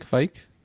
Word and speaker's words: Fike: Dominic Flick. Like, his name is a Flick or Flake? – Dominic Fike? – Fike? Okay, I got his name wrong both ways.